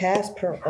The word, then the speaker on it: Kasparov: Kasparov.